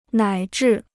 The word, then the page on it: 乃至: nǎi zhì